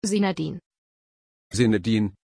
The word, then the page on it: Zinedine: German